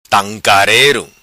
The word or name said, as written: Tancarero